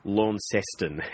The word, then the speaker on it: Launceston: And just for the record, it's Launceston. It's a soft C, not a hard one.